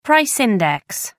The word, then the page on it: price index: American English